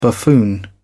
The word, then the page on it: buffoon: British English